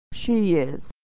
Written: she is

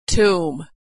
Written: tomb

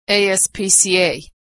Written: ASPCA

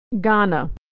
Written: Ghana

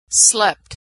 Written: slept

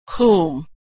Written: whom

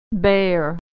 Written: bare

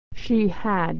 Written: she had

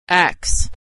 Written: acts

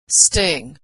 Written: sting

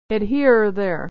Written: or there

